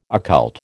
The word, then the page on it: occult: American English